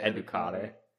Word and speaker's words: educare: Educare? I would say educare. Okay, it wasn't too terrible.